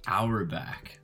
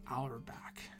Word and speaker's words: Auerbach: How do you pronounce his last name again? – Auerbach. – Dan Auerbach. Great.